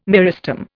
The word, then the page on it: meristem: Indian